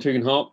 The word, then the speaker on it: Tugendhat: I began by asking Tom whether I pronounced his surname, Tugendhat, correctly. – I say Tom Tugendhat, but others say many different things, and some of them are even, printable.